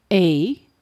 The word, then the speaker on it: Aodh: A, which is spelt in Irish as A-O-D-H, meaning born of fire.